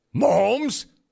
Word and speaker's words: Mahomes: It's actually pronounced Mahomes.